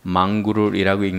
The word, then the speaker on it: mongrel: 그리고 이 하이브리드의 다른 말은 mongrel, M-O-N-G-R-E-L 이것이 있는데 이게 망그롤이라고 읽는데 이 망그롤은 잡종이란 뜻입니다.